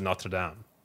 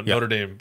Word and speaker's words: Notre Dame: But my thing is, if you're speaking English, you almost kind of take the English pronunciation of things. So, for me, Notre Dame, right? It's like the Notre Dame football team, right? Like, the people who formed it in Notre Dame, you know, Notre Dame football. So I pronounce it Notre Dame. If I'm speaking French, then I say Notre Dame.